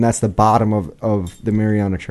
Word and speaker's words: Mariana: Is that how you pronounce it? – The Mariana.